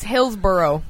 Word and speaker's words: Hillsborough: It's Hillsborough. – That allege? – Allegedly? – A-L-E-G-E.